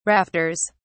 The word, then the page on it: rafters: British English